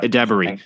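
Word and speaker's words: Edebiri: Am I saying it right? I need to get used to it again. – Well, hold on. – Idebiri. – Idebiri. We've been saying it wrong for 2 years. Idebiri. And no one corrected us. – No, I think it's Idebiri, right? Yeah. Yeah. Exactly. Io Idebiri.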